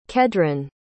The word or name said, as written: Kedron